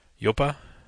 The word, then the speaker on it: Joppa: I brought from Newburyport Brewing the Yopa Grande. – Yopa? – Yopa? – Yopa? Or J-O-P-P-A. – Yopa? – We're going to say it like we're just dumb Americans. Yopa.